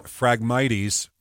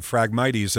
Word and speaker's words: Phragmites: Fire crews started burning Phragmites. Maria, did I get it right this time? – You got it right. – I've been mispronouncing this for a decade. Phragmites at around 9 this morning.